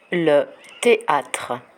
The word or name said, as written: le theatre